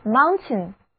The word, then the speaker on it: mountain: 我们知道 AIN 是一个名词后缀，那加在表示山的词根 mount 的后面，得到的单词就是山脉，读作 mountain mountain。注意哦，这里的字母组合 ai 的读音是短而急促的 a。